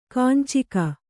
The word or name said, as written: ಕಂಚುಕ